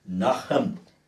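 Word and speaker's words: nacham (נָחַם): And when you do a word study on the word repent, according to the Hebrew word, which is nacham, N-A-C-H-A-M.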